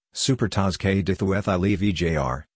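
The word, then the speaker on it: Supertaça Cândido de Oliveira: The Supertaça Candido de Oliveira, Portuguese pronunciation, Supertaça Candido de Oliveira,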